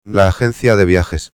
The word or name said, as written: la agencia de viajes